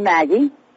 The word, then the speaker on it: Maggie: I'm Maggie. – Maggie? Can you spell that for me? – M-A-G-G-I-E. – M-A-D-D-I-E.